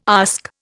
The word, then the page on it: ask: Indian